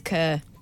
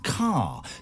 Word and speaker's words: Kerr: Judith Kerr. – Wrong. Incorrect. Incorrect answer. – Kerr. – Incorrect answer. – Go on, then. – It's pronounced Judith Carr, right. Despite being spelt K-E-R-R.